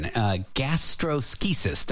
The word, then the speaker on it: gastroschisis: So this condition, gastroschisis. Did I say that right? – Said it perfectly.